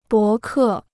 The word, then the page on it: 博客: bó kè